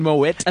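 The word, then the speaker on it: Moët: And then Moet.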